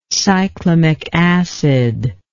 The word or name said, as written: cyclamic acid